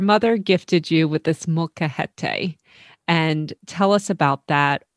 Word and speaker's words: molcajete: But even in your book, your story starts with the gifting of is it called a molcajete? – You're so close. It's a molcajete, and it's molcajete.